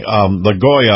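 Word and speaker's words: Lagoa: He's going to interview our Florida justice come Friday, LaGoya, I believe is the way she pronounces her name.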